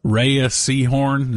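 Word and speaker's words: Rhea Seehorn: I think Rhea Seahorn, is that how you pronounce her name? – Mm-hmm.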